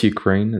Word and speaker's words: Ukraine: It's just Ukraine. It's not the Ukraine.